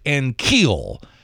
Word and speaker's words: Ankiel: It's Rick Ankiel. All right, accent on the first syllable.